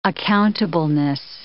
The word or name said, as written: accountableness